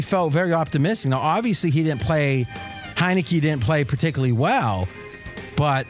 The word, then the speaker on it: Heinicke: How do you pronounce his name? – Heinicke. – Heinicke. I was thinking Heineken, and I'm like, it couldn't be Heineken.